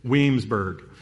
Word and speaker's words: Williamsburg: Or as they say down there, Weemsburg. It's two syllables, Weemsburg.